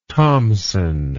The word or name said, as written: thomsen